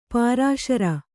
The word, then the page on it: ಪುರಃಸರ: kannada